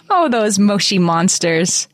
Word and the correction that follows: Moshi: They're actually moshy like monsters. Bubbles. We're supposed to, we should know better to say it correctly. Moshy monsters.